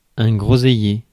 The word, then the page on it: groseillier: /ɡʁɔ.zɛ.je/; France